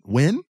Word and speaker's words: Nguyen: Viet Nguyen? – Nguyen, yeah. – It's not Nguyen, it's Nguyen. I'm guaranteed it's Nguyen. – He's right. You don't pronounce the N.